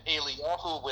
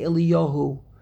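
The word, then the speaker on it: Eliyah (אליה): But there we read Eliyah. There we don't change the pronunciation. There we don't say Eliyahu. There in the Haftarah, the way it is spelled is the way we pronounce his name.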